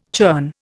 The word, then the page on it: churn: Indian